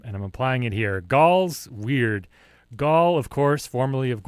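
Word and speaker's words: Wyrd: Following that up was Gauls Weird, I think is how you pronounce it. It's W-Y-R-D. I think it's, I look at it and I look at like, if I have any real nerds in the audience, you might know the name for a dragon that has no wings is a Wyrm.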